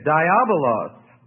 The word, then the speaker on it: diabolos: And in the Greek, it is diabolos, which is spelled D-I-A-B-O-L-O-S.